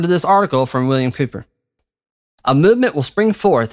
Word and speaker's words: Cowper: William Cooper, a lot of people think that's Cowper because of how it's spelled, but it's Cooper.